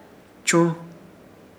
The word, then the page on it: Churz: Ishir Chamacoco